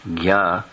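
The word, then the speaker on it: sarvajña: Sarva-gya, the syllable G-J-N-A, gya, means to know.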